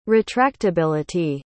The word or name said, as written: retractability